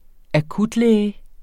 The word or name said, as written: akutlæge